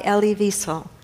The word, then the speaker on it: Elie Wiesel: By Elie Wiesel.